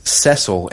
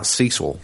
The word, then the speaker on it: Cecil: Well Cecil's the English pronunciation. – Cecil. – Cecil is actually how they pronounce it in the game, too, though.